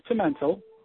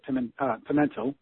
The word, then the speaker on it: Pimentel: Yeah, it's Pimentel. The actual name was originally Pimentel, and it depends what part of the country you're from, but I grew up pronouncing it Pimentel. – Yeah. – And it's Portuguese.